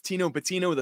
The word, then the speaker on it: Tino Pattigno: My name is Tino Pattigno III. Yes, it rhymes, and I'm currently based on Long Island, New York.